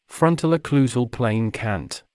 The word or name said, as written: frontal occlusal plane cant